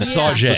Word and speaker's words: Sauget: And the other one that is pronounced correctly is Sauget. – Mm-hmm. – Randomly. – Yeah. – Carondelet. But Sauget. – Sauget. – I don't know how they get this.